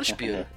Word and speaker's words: Spyro: Então, o Spyro, o Spyro, né? dependendo da pronúncia. Eu falo Spyro. então vamos seguir essa linha aí.